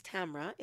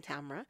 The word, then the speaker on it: Tamra: My name is Tamra. It's my birth name. My mother named me Tamra. Actually, my grandmother named me Tamra. Rhymes with camera.